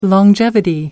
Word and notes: longevity: American English

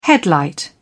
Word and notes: headlight: British English

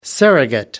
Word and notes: surrogate: American English

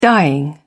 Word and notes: dying: British English

